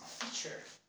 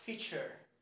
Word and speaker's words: feature: This is feature. Feature. Okay. Like picture. Feature. Okay. Picture. Like feature.